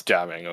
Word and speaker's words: Gaming: It's jamming, okay? – Oh. – That's how you pronounce it as a real Chinese person. It's jamming.